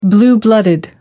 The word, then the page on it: blue-blooded: American English